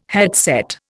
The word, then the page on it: headset: Indian